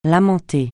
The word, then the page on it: lamenter: francais